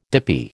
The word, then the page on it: dippy: American English